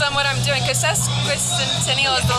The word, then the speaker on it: Sesquicentennial: I have messed this up every single time. I know how to say it. I told you, I sound dumb every Friday at 5 a.m. Congaree National Park or Sesquicentennial? That's it.